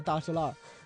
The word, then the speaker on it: 大栅栏: 其实说到大石栏的读音，就是本地人都叫大石栏，然后其实它就根本就不是字面上那个大栅栏，就是这也是老北京话的一个魅力的体现的地方，就是一是儿化音，然后二是方言的一种演变。就是大石栏